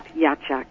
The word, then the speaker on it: Yachak: But it's quite various, but it's pronounced Yachak.